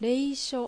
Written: Reisho

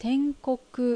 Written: Tenkoku

